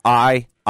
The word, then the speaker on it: Iisalo: So obviously the spelling of his last name is Iisalo. So it seems to me like you have a... – He has a long I when he says it.